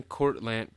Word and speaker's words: Cortlandt: Woodlawn Cemetery in Van Cortlandt Park, or Cortland. It's spelled like Cortland, but with a T at the end, too.